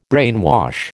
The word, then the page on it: brainwash: American English